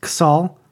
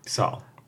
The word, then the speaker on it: Ksal: It's spelled Z-A-L, etc. And it's mentioned in the paragraph of a different fanfic that please pronounce Ksal as Ksal. – Ksal. – Written out as K-S-A-L, not S-H-A-L, not Z-A-L.